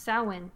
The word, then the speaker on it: Samhain: Samhain. It's pronounced Samhain. – What? Why did they put an M in there? – I don't know.